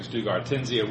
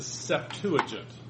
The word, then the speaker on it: Septuagint: This is called the Biblical Hebraic Septuagintia, which is really just a form of that Rabbinic Bible, just an updating of it.